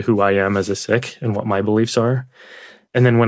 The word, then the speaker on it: Sikh: He's Sikh or Sikh, as some people pronounce it. He pronounces it Sikh.